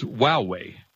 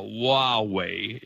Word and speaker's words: Huawei: which is Huawei.